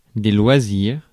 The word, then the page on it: loisirs: France; IPA: /lwa.ziʁ/